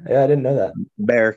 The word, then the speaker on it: Bexar: b-e-x-a-r bexar bear the x is silent